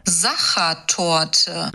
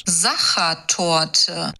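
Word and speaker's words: Sachertorte: On the food calendar, it's National Soccer Tort Day. I'm probably saying that wrong. – Zacha Tort. – Okay, well, it's celebrating the chocolatey dessert that was created in Vienna in the 1800s. – Zacha Tort.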